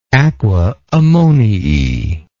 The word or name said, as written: aqua ammoniae